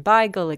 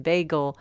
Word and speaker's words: bagel: Because it originally comes from the Yiddish, Yiddish can be pronounced in different ways. And so according to where the Yiddish was spoken, it could have been beigel, it could have been bagel.